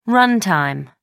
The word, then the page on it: run-time: American English